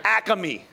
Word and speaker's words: Acme: Because some of us said Acme, and then others of us from Philly, well, how do you say it? Acme. They're like, dude, that's a full sentence, what you just said, right?